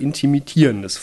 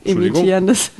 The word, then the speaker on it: intermittierendes: Heilfasten oder intermittierendes Fasten? – Intermittierendes Fasten meinst du? – Ja, Entschuldigung. Imitierendes.